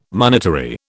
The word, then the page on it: monetary: British English